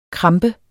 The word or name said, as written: krampe